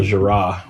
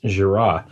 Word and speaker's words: Gira: The only constant being vocalist and songwriter Michael Girard, which, funny enough, up until I saw this this documentary, I had been saying his name wrong for 20 years now, which I had always said Gira. It's G-I-R-A, but, you know, hearing other people, including himself, say his last name, I found out it's pronounced Girard.